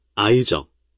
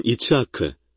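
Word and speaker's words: 이츠하크 (יצחק): 이츠하크, 이츠엑는 웃음이라는 뜻이고 여러분은 이츠하크, 아이적, 라고 발음하는데 실제로는 이츠하크, 이츠엑입니다.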